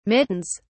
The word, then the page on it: mittens: British English